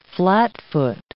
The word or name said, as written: flatfoot